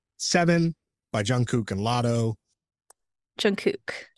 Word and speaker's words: Jungkook: No, but it's it's Jungkook. That's it. That's it's what whatever he was saying was just incorrect because he was like saying kook, right? – Yeah.